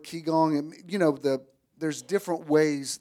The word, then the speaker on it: Qigong: Qigong postures, thank you.